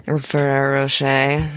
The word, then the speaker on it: Ferrero Rocher: So the companies that have seemed to do more work for this that I found were Mars, Cadbury, Ferrero Rocher. I'm saying that wrong, probably. – Ferrero Rocher. – There you go.